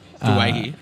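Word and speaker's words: Doueihi: Dewey. – Well, I think he changed it to Duay he. – Duay he. – Yeah, I think that's what it is. That's the Lebanese pronunciation.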